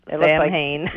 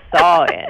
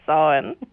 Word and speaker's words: Samhain: No, because it looks like Samhain. – Yeah. – It's Samhain. – It's Samhain. Yeah.